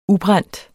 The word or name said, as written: ubrændt